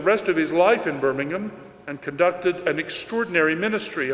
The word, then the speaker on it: Birmingham: After casting about for the best place to found his new community, Newman moved to the great industrial city of Birmingham, or as the British pronounce it, Birmingham, and there he founded the first oratory in England.